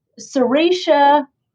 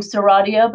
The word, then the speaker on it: Serratia: In the past, I think we were worried about Morganella, Serratia, which I guess I should be pronouncing Serratia, but I'm still a Serratia person.